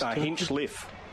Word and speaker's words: Hinchcliffe: Hinchcliffe. There's Hinchcliffe. No silent C.